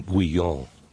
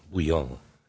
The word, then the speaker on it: bouillon: Yes, the slurping sound you heard was my chicken bouillon. Bouillon. We pronounce it all Frenchy like here.